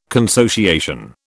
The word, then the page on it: consociation: British English